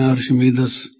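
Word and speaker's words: Archimedes: अर्शमेदीज, कई उसको प्रोनाउंस करते हैं अर्शमेदस,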